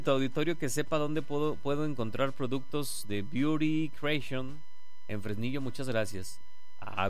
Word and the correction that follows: Beauty Creations: O Beauty Creations. Creations. Sí, sí se pronuncia así.